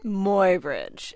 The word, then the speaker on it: Muybridge: Muybridge. Edward Muybridge. – Right, and Edward, E-A-D-W-E-A-R-D, Edward.